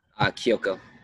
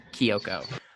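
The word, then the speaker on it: Kiyoko: And then Haley, I never say it right, Kyoko? – Kyoko. – Kyoko.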